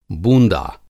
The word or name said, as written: बूँदा